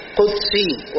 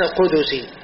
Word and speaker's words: قدسي: ده حديث قدسي، الصحيح قدسي وليس قدسي. بعض الناس يقول في الحديث القدسي بضم الدال، لكن الصحيح بسكون الدال، حديث قدسي، في الحديث القدسي هكذا تنطق.